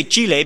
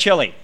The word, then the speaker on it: Chile: I say Chile because if you speak Spanish or if you're Latin American, that's the proper pronunciation for the word. However, if you're just a regular American from Lowell, Indiana, or thereabouts, it is just Chile.